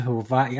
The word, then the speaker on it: Hawaii: I've never been to Hawaii. I didn't know that the W was pronounced with a V.